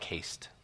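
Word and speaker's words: hesed: Now, that word that I used, haste, it's spelled H-E-S-E-D. H-E-S-E-D. It's a Hebrew word, but it's pronounced like haste, like speedy, fast, right, when you're rushing.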